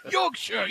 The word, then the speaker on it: Yorkshire: Say it like this. Say like Yorkshire. – Yorkshire. – Yes. It's you're gasping at your last breath and you say Yorkshire.